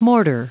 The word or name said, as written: mortar